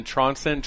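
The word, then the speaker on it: Trondsen: The best thing, I always tweet out all our interviews, is just go to @myname, it's Chris, and then Tronson, Tron like the old school movie, silent D, S-E-N.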